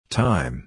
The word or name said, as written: thyme